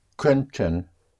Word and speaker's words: könnten: Könnten is spelled K-O umlaut, double N-T-E-N, könnten.